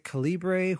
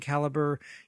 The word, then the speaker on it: Calibre: Now, some people pronounce it Calibre, which I guess is okay, but I've heard the guy that does the Calibre project call it Calibre. So that's C-A-L-I-B-R-E, if you wonder why I'm making a big deal of the pronunciation.